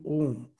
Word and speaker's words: Om: So just when you're breathing out, mentally go on repeating Om. So the om has these three syllables, a, u, m.